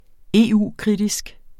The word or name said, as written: EU-kritisk